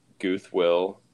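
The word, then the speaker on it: GuthWill: So yeah, my YouTube channel, Guth Will, which is spelled G-U-T-H-W-I-L-L, I think.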